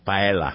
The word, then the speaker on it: paella: There's an L, paella.